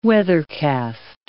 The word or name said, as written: weathercast